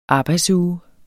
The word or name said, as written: arbejdsuge